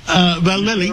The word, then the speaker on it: Vallely: Well, Major General Paul Vallely, did I say it right? Vallely. – You're right, yeah.